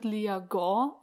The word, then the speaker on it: Liagon: Also das wird Liagon ausgesprochen, ungefähr. Also ich kann es, ja, es klingt bei mir wahrscheinlich auch nicht hundertprozentig richtig.